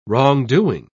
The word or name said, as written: wrongdoing